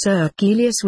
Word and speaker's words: Circilius: The nearest he could get to her name was Circilius, my spelling, pronounced as Circilius with the second syllable stressed.